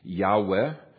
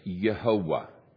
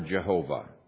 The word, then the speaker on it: Yehovah: Either one is a legitimate pronunciation of that. And there's three syllables. If you look even at how God forms the foundation for many of people's names, it's one of the proofs that there's three syllables, not two. Yahweh is two syllables. Yehovah or Jehovah is three.